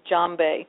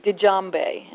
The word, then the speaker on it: djembe: It's spelled with a DJ, but most people say, the correct pronunciation is djembe. Most people say djembe, and that's incorrect.